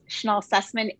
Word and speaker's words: Schnoll-Sussman: Hi, this is Felice, and I'll just Caitlin, my last name is so hard to pronounce. So it's Felice Schnoll-Sussman.